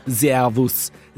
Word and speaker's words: Servus: Io credo sia Servus, letteralmente Servus, che si pronuncia in austriaco Servus.